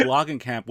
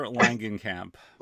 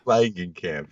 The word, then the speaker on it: Langenkamp: Actually, I think Loggenkamp was their native German pronunciation. – Yeah. – Once she became a star, Heather Langenkamp. – Langenkamp, yes.